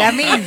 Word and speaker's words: Lamine: Para Yamín. – Claro. – ¿Lo dije bien? – Sí. – No. Lamine.